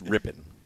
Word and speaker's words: Rypien: It's Rypien. – Rypien. You sure there's an I there? – I know how it's spelled, but it's Mark Rypien and Brett Rypien, yeah.